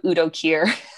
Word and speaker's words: Udo Kier: I haven't seen that film in a while, so correct me if I'm wrong, but also Udo Kier, right? That's how you pronounce his name? The villain? Oh, fuck, I have now look it up so I don't look like a fool. Oh, yes, I got it right.